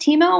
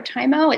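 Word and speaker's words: Tiimo: It's pronounced Timo or Timo. It's T-I-I-M-O.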